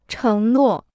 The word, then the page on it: 承诺: chéng nuò